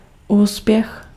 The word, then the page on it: succes: [syk.sɛs]